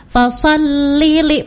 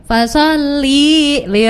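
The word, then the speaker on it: fasallili (فصل لربك): Khususnya untuk ayat yang kedua ini, fasallili pendek dua-duanya, fasallili rabbika wanhar. Yang jadi problem adalah ketika ada beberapa guru yang tidak memegang Quran, mengingat-ingat dari hafalan yang beliau hafal ketika kecil. Jadi bacanya kayak gini, fasali li rabbika wanhar.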